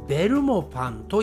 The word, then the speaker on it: ベルモパン: はい、えー、これはね、少しやはりアクセントが違います。日本語では、日本語ではというか、日本人の発音ではベルモパンと言いますね。